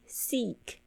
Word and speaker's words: seek: 然后 doesn't seek revenge，这个 doesn't 就是 does not 哦，合在一起讲 doesn't seek revenge，这个 seek 注意一下 e 的发音 e seek 哦，就是寻找、寻求的意思。